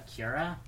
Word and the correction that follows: Quora: Is that how you pronounce the website? – Quora.